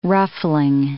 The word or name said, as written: ruffling